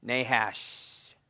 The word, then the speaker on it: Nahash: And the thought behind that is a little bit because of how you say his name, Nahash, okay? So it has a little bit of a hiss to it as well.